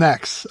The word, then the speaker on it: X: And, you know, clearly the biggest difference is between Microsoft and Apple is how do you pronounce the letter X? Is it is it an X or is it a 10? – It is an X.